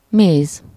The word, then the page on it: honey: [ˈhʌ.ni]; American English